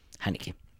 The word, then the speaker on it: Haneke: Haneke. Es como se pronunciaría en alemán, ¿vale? Es que solemos decir Michael Haneke.